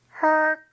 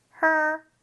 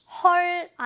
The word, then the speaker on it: her: Her car. 여기가 조금 발음이 her, 헐 아니고 her, 혀가 안으로 굴리는 소리가 납니다.